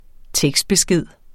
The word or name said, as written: tekstbesked